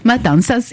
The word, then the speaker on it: Matanzas: On va commencer par cette ville d'où vous venez qui s'appelle Montazas. Je prononce très mal ou juste? – Matanzas,